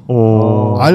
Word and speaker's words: or: O 하고 그냥 장모음으로 써요. – 오. R 발음이 아예 없어요. 전혀.